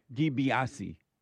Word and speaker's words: DiBiase: Ted Biasi. DiBiase. Derek said, it's $1 million man, Ted DiBiase. – Okay, and we're trusting him. We're trusting Derek, the black producer's pronunciation? – Yeah. – I don't trust mine either. – So what, Derek? He said that's how he says it. – Okay, nice. Ted DiBiase.